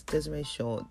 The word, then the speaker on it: Disree Shaw: That's Desiree Shaw, D-I-S-R-E-E-S-H-A-W. And yes, it's D-I-S-R-E-E.